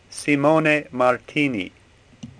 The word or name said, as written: Simone